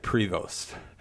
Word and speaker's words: Prevost: I said if an American is to be chosen, I like Prevost. Now, I called him, unfortunately, Provost. I mispronounced his name.